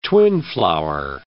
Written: twinflower

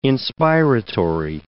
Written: inspiratory

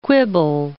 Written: quibble

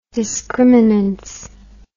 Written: discriminance

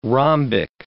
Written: rhombic

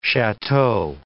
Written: chateau

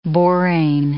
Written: borane

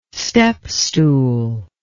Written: stepstool

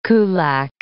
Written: kulak

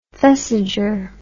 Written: thesiger

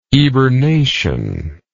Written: eburnation

